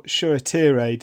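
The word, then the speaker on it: Shoretire: So this was Shola Shawtire. That's how it's pronounced, is it? – Yeah, it seems to be either Shawtire or Shuatire, depending on which commentator you listen to.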